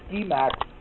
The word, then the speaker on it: Guimax: Their pronunciation is Guimax. Some in the U.S. pronounce it Guimax or Guimax, but it's actually Guimax is the French pronunciation of that ladder builder.